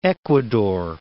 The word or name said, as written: Ecuador